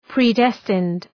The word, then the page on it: predestined: prı’destınd